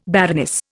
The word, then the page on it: baroness: Indian